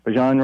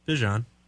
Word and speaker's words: Bijan: So Bijan Robinson, whatever, however. – You said it right. – Robinson. – Bijan. – Dijon or Dijon? – Dijon. – Mustard. – Well, it's with a B.